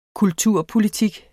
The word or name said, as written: kulturpolitik